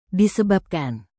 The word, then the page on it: disebabkan: indonesian